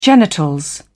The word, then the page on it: genitals: British English